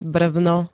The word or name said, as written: brvno